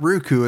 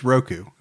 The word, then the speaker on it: Roku: It's not Roku, it's Roku.